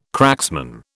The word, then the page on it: cracksman: British English